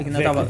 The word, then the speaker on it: Vecchia: Como é que fala? É Casa, casa Vecchia? Vecchia. Vecchia. Vecchia é meu sobrenome.